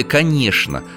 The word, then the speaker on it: конечно: Сочетание букв «ч», «н» в словах часто произносится как «шн». Не только в слове «конечно», но и, например, в словах «скучно», «скворечник», «яичница».